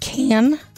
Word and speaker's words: Cannes: Gavin, is he saying, is he talking about Cannes? – Yeah. – France. – Correct. – S is silent.